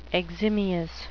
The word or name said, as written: eximious